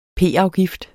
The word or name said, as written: p-afgift